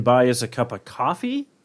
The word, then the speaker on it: Kofi: It's called Coffee, but it's spelled K-O-F-I.